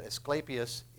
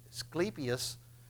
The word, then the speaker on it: Asclepius: Now, on top of this, we're at a place where the temple of Asclepius, you pronounce that Asclepius in Greek, you pronounce it Asclepius in Latin. It's a little different, but it's the same temple.